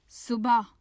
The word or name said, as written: subah